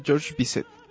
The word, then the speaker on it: Georges Bizet: Algo así se pronuncia, Georges Bizet.